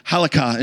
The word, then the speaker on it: halakha: Now, for the Jewish believers, like this was a very familiar, they had a word for it in Hebrew, halakha. Anybody speak Hebrew? Okay, that's how you pronounce it. Halakha, and it means literally to walk.